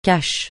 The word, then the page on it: cash: francais